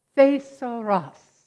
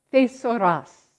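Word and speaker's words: Thesauros (θησαυρός): Thesauros. Thesauros. Sounds like what English word? Thesaurus.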